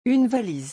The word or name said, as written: Une valise